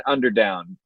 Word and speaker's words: Underdown: And we've got Cameron Underdown. Did I say that right, Cam? – You got it.